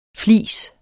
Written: FLISERNES

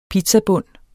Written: pizzabund

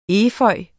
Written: efeu

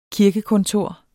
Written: KIRKEKONTOR

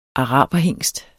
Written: araberhingst

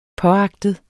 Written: påagtet

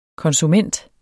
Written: konsument

